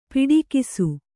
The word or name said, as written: ಪಿಡಿಕೈಸು